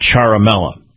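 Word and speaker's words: Ciaramella: You pronounce it Charamella.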